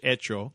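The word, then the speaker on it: Hecho: And what's great about, and it's H-E-C-H-O, but it's pronounced in Spanish, Echo.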